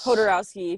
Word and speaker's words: Jodorowsky: Jodorowsky, if you use the Spanish pronunciation.